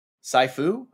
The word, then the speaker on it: Sifu: S-I-F-U. Sifu? – Sifu.